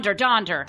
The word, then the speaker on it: Donder: Dander was the answer to that. – Poor Dander. – No, Donder. Not Donder. Not Dander. Whoops.